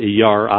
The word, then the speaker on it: Iyar: Yeah, they call it Iyar, I-Y-A-R, it's spelled.